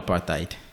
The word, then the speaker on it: apartheid: Yes, apparently apartheid. That's how it's pronounced. – Apartheid? – Yeah, no, apartheid. – I see. – Because it's Dutch. It's a Dutch word, I think.